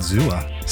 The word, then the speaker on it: Xooa: Associated Press is launching an NFT marketplace built by Zooa. That's how you say it, right? – That's how you say it, Zooa. Spelled Zooa. X-O-O-A.